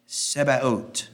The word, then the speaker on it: Sabaoth: Number 9 is Yahweh Sebaot. That word looks almost like Sabbath, but it's pronounced very much differently. I'll write it here. – S-A-B-A-O-T-H. And as I understand, the H is silent. Sebaot.